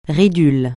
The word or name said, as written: ridule